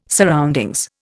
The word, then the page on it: surroundings: Indian